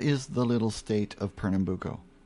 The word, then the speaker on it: Pernambuco: So that's called Pernambuco.